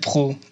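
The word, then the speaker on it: Prott: Ouais, je m'appelle Pro. Et ça s'écrit pr 2 T. Alors, je vous laisse faire le calcul mental, mais ça ressemble à Prout, effectivement.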